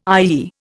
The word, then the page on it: IEE: Indian